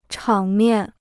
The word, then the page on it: 场面: chǎng miàn